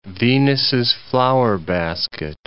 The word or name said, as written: Venus's flower-basket